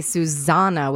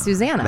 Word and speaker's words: Susana: Well, because it's spelled S-U-S, because you want to say Susana with a Z.